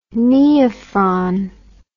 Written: neophron

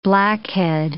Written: blackhead

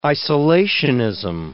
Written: isolationism

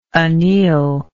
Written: annealer

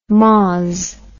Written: moz

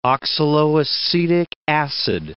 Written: oxaloacetic acid

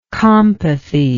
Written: compathy

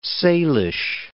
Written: Salish

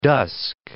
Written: dusk

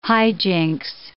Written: hijinks

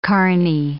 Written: carney

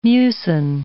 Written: mucin

